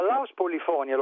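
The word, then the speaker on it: Laus Polyphonia: Allora, Laus Polifonia, lo pronuncio come viene comunemente chiamato nelle Fiandre,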